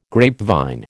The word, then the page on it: grapevine: American English